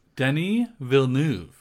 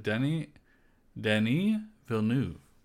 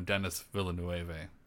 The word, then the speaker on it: Denis Villeneuve: Denis Villeneuve. He's French. – Why does he go by Denis Villeneuve? – He doesn't. That's how you pronounce it. You pronounce it Denis Villeneuve. He's from Quebec.